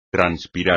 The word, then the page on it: transpirar: espagnol